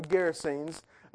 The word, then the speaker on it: Gerasenes: It says in verse 26, and they sailed to the country of, and Brandon, you did a good job a while ago, Gerasenes.